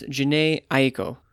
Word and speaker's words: Jhené Aiko: Her name is pronounced Jhené Aiko, and the title of the album is Chilombo.